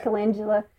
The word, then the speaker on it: calendula: Calendrum. Did I say that right? – I say calendula.